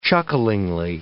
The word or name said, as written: chucklingly